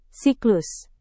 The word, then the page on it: siklus: Indonesian